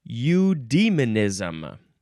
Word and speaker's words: eudaemonism: Eudaemonism. Eudaemonism. Eudaemonism. And you can also spell it Eudai for some reason. And you would pronounce that one eudaemonism.